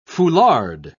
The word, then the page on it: foulard: British English